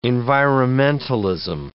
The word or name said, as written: environmentalism